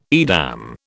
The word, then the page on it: Edam: British English